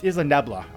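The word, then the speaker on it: Isla Nublar: Yeah, it's Isla Nebla. – There we go. – That's how you pronounce it.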